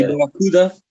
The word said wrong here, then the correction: Baruchel: You were saying, isn't he Jay Baruchel?